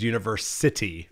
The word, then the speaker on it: UniverCity: I say it as UniverCity Alliance. – Great. – I just put the emphasis on city.